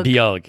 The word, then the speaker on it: beag: Am I pronouncing that right? – Oh, Bjug. – Bjug. – Bjug. It's, yeah, it's Irish for small. – Ah, Bjug. Oh, okay. B-E-A-G.